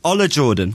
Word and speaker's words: Ola Jordan: How do you pronounce it? Ola? – Ola Jordan.